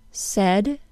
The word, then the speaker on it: said: Since the word said is non-phonetic, it is included in the short E lesson, along with the words says, friend, guest, and again.